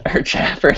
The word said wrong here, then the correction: chamfered: I wasn't even paying attention when you pronounced it. I was like, I wonder how you pronounce that word. – Is that what you said? – I think it's chamfered. – Chamfered. – Pretty sure it's chamfered.